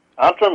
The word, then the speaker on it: Antrim: Correct me if I'm wrong, but it looks like it's pronounced Antrim, Northern Ireland. – Antrim, yes.